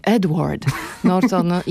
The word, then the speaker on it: Edward Norton: Seth Rogen, Penelope Cruz, Edward Norton, tak z Polska wymawiam to imię, Edward Norton